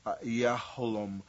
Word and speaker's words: Yahalom: Now, we covered this the last time, that the diamond may have been a moonstone because it was engraved on it, and the name of it is pronounced Yahalom.